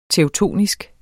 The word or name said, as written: teutonsk